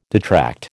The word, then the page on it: detract: American English